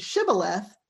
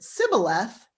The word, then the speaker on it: shibboleth: If he pronounces it shibboleth, they let him pass. But if he pronounces it sibboleth, they know he's an Ephraimite trying to sneak across and they kill him.